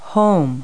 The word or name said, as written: home